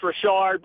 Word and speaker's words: Rarichard: It is actually spelled like Richard, R-A-R-I-C-H-A-R-D, but it's the French pronunciation, if I'm not mistaken, right? – That is correct. It's pronounced Richard, but I've been called Richard, Richards, Richard.